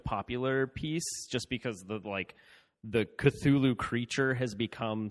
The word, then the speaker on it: Cthulhu: I mean, there's the, I'm going to mispronounce it, but there's Cthulhu, how do you pronounce it? – Cthulhu? – Yeah, Cthulhu.